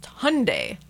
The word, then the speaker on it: Hyundai: Just a few years ago, remember Hyundai, they were like, it's pronounced Hyundai, like Sunday.